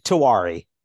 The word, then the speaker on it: Tiwary: Tiwary. That one's more like how it looks.